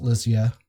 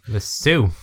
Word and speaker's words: Lisieux: And it's pronounced Lizia for our American listeners. We do love you, though. – Lisu. – Therese of Lisu.